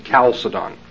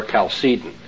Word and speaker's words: Chalcedon: This perspective explains the name of the foundation, Chalcedon, or Chalcedon, as he prefers to pronounce it.